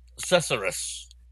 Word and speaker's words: Susurrus: The Susurrus. S-U-S-U-R-R-U-S. – That's how I would pronounce it.